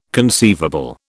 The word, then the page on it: conceivable: British English